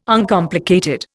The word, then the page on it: Uncomplicated: Indian